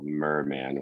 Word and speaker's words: Myrrhman: I would say this song called Merman, which is spelled M-Y-R-R-H-M-A-N.